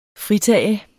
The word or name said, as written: FRITAGEN